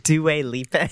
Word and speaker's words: Dua Lipa: Dua Lipa.